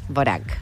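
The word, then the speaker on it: Dvořák: No sé la verdad bien cómo se pronuncia. – ¿Dvorak? – Bueno. – ¿Dvorak? – Sí, me suena... Debe ser... – Me suena que es una D muda.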